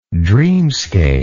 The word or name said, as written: dreamscape